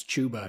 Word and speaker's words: Chuba: Okay, I spent 2 years correcting people on how to correctly pronounce Chuba, because I talked to him like early. I'm like, no, it's Chuba, it's not Chuba.